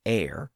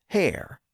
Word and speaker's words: heir: He says, in her forehead, armed and reverted, making war against her heir, H-E-I-R. Now, the reason why this is a pun is because the word heir could be pronounced with a silent H, like today, but also with a pronounced H at the beginning. So some people said heir, and some people said hair, sort of like herb and herb today.